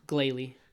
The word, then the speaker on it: Glalie: Glalie. – Glalie is going to be from December 15th to January 1st.